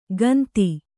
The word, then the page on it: ಗಂತಿ: kannada